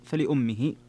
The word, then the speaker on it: فلأمه: قراءة الجمهور فلأمه، وتفرد حمزة والكسائي فلأمه بكسر الهمزة، فلأمه السدس.